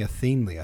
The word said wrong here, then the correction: Athelney: I think, but it's Athelney.